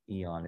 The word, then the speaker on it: aeon: There's also this idea of a consort of the goddess, another aeon, if you want to pronounce it that way. You can pronounce it in both ways. Really, probably aeon is more appropriate.